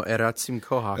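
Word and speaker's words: Erazim Kohák: By Ratzim Kohak. I don't even know if I said that right. – Well, you did. It's a Czech name, and you did the Mexican. – No, Eratzim Kohak.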